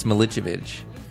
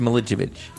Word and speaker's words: Milicevic: No, but you're saying you're butchering my name. – Am I? – Yeah, it's Milicevic.